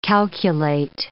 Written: calculate

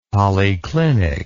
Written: policlinic

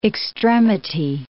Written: extremity

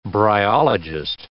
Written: bryologist

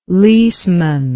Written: leaseman

